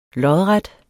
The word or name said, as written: lodret